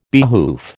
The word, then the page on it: behoof: American English